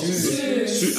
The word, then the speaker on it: Siu: S-I-U, spricht man S-I-U, S-I-U, wie spricht man es aus? – Sü. – Ach, das ist Sü? – Ja.